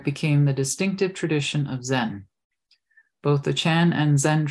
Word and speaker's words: Zen: Chan and Zen. Chan means meditation. It's the Chinese transliteration of the Sanskrit dhyana and is pronounced Zen in Japanese, San in Korean, and Tien in Vietnamese.